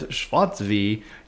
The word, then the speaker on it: Schwarzee: Pronounce Schwarzy as Schwarzy. Yes, Schwarzy rhymes with our favorite game, Yahtzee.